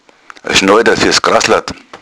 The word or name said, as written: schnoida